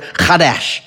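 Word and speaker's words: chadash (חדש): It's the word "chadash." If you say it right, you spit on your friends.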